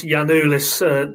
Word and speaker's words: Giannoulis: I think you'll find it's Giannoulis, Dave. And we only know that because we had this on Friday and we had a Greek native speaker put us right, Connor? I think, yeah, on our Friday show. – They were lauding pronunciation of it. Mine not. No, there you right. Go. That's apparently, ask on anything apparently, Greek. Y, yeah, apparently the G-I-A is pronounced Y, but that's about the end of my linguistic skills, so I won't be telling anybody how to pronounce anything.